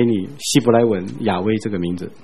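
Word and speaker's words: 雅威: 伊的本体还有一个名，古有差不多 7000次讲到一个名，就是耶和华。 – 现在的学者认为应该回到他最初犹太人给这个神的名字的读音，叫做亚威。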